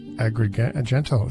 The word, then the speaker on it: Agrigento: That would be Agrigento. It's got to be Agrigento. – Agrigento.